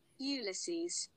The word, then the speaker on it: Ulysses: Ulysses.